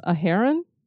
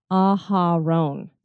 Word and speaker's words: Aharon: I had said that I thought maybe it was a heron, because it kind of looked like Sharon with an A, but it actually is Aharon.